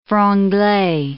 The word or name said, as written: franglais